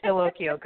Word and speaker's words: Dallocchio: Yes, it's Dallocchio.